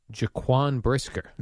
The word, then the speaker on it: Jaquan Brisker: I love Bears safety, Jaquan Brisker. – Nice job on the pronunciation. – Did I nail that? – Not Pau Gasol.